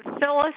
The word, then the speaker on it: Phyllis: Phyllis Tietzel, T-I-E-T-Z-E-L. – Okay, how do you spell Phyllis? – P-H-Y-L-L-I-S.